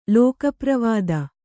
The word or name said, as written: ಲೋಕಪ್ರವಾದ